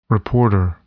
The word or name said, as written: reporter